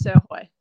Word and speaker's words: Srhoj: So heaps of people do butcher it, but I usually get the Srhoj, but pronounce Srhoj.